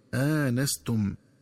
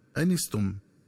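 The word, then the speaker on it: آنَسْتُمْ: ينبغي التنبه للنطق الصحيح للفظ آنستم، فيقرأ بهمزة مفتوحة بعدها ألف مع فتح النون هكذا آنستم، ومن الخطأ قراءته بحذف الألف وكسر النون هكذا أنستم.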